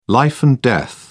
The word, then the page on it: life and death: British English